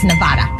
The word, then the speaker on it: Nevada: Las Vegas, Nevada. – That's correct. Yes. Mm-hmm. – Said Nevada, which is actually the correct pronunciation.